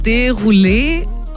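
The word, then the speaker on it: Desrouleaux: Und sein Nachname wird, ich glaube, Derulé ausgesprochen. Derulé? Also geschrieben wird Desroleux.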